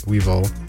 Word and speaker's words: Weval: The song you're listening to right now is also by Weevil, or I believe that's how it's pronounced. It's spelled W-E-V-A-L.